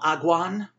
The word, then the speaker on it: Agwhaan: It is now Agwan, which is spelled A-G-W-H-A-A-N.